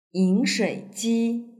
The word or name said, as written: yinshuiji